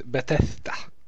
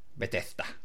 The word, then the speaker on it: Bethesda: Bethesda. – Bethesda.